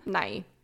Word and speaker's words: Nighy: Nighy. – Bill Nighy, like the science guy.